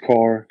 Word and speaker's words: Kerr: It's James, K-E-R-R, but it's pronounced Carr.